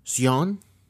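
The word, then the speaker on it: Sjón: Now, this movie is co-written by Robert Eggers alongside Sion. – I think there's an accent on the O. – Yeah, so S-J-O-N is the spelling.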